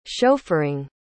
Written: chauffeuring

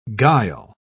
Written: guile

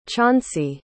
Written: chauncey

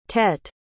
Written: tete

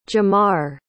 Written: jamar

